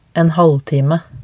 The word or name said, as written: en halvtime